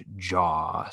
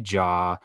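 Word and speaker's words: Zhao: Just because, same thing, and her last name is pronounced Jaw. So Chloe Jaw, I think she's fantastic.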